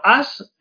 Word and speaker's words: Us: Bueno, As, que se escribe Us, pero se pronuncia As en inglés, vaya noche, que es, bueno, vendría a ser nosotros, o nosotras, o a saber.